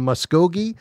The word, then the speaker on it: Muskogee: Mike is in Muskogee, Oklahoma. Did I say that right, Mike? – Yeah, you said it right.